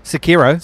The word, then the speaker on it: Sekiro: Sekiro.